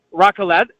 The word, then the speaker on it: raclette: You have raclette. I didn't realize that, or am I saying that right? Raclette? – Pretty good. It's the cheese. Not bad.